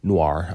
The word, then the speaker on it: noir: And so, which funny, if you look up the old clips, Eddie Muller used to say noir, like noir, you know, like that's the way we sort of pronounce Renoir, and you know, that word O-I-R, usually it would be pronounced like oir, memoir, film noir, you know, the artist Renoir. And in recent, as the show has gone on, he says it more the way I typically say it, which is noir. I pronounce the R, and it's interesting.